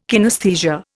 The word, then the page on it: kinesthesia: Indian